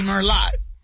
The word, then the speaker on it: Merlot: It'd be Merlot. It says Merlot. – I guess so, yeah. – Yeah, remember Merlot is, and that T is silent the T on the end silent. Of Merlot.